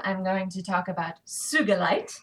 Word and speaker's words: sugilite: Sugalite, often mispronounced sugilite, was discovered in 1944 by Japanese gemologist Kenichi Sugi. And it's a hard G, and it is mispronounced all the time because no one knows how to pronounce Japanese names. No one outside of Japan. So it's sugalite. It is an opaque to translucent crystal.